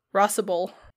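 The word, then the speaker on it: Rhosybol: Give me the pronunciation, please. – Tell me how to say it. – Rossible. – I was right? – Yeah. Rossible.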